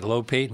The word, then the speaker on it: Lopate: Well, my name in Russian was Lopata, but when it was spelled out, not in Roman letters, L-O-P-A-T-E, people here started pronouncing it Lopate. My grandfather said, okay, so it's Lopate.